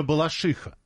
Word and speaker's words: Балашиха: А на первый слог ударение точно не будет. – Вот мне кажется, одно точно, не первый вариант. – Не первый слог. – Да, 319 говорит, нет, точно Балашиха.